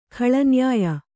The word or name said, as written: ಖಳನ್ಯಾಯ